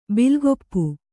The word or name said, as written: ಬಿಲ್ಗೊಪ್ಪು